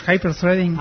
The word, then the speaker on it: Hyperthreading: ¿Le preguntaste allí a ver cómo se decía? – Sí, pues Hyperthreading. – Ah, sí. – Está bien pronunciado.